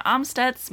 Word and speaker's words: Amstutz: And I'm Sandra Amstutz.